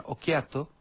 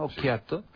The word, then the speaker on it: Occhiato: Entonces, se queda, su hijo, se queda, que se llama Ciro, le llaman Occhiato. Fíjate cómo pronuncia italiano, Occhiato.